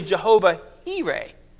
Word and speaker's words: Jehovah Jireh: If you pronounce it the American way, it is Jehovah Jireh. If you pronounce it the actual literal way, it is actually Jehovah Jireh. The J makes a H sound.